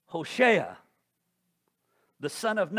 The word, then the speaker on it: Hoshea: Did you mispronounce that? Did you suddenly sneeze in the middle of trying to say Joshua? No, I did not. It is Hoshea.